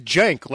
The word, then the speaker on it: Cenk: Yeah, I always said Cenk, like with a J.